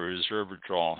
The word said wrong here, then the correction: resveratrol: I'm pronouncing that wrong. Resveratrol.